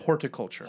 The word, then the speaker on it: horticulture: Is that a horniculture? – You thought it was horniculture? – Yeah. – No. – Like H-O-R-N. No. – Horticulture. – Horticulture. – Yes. – Huh. – Huh. – I've been pronouncing that T and N my entire life.